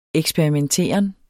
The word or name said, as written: eksperimenteren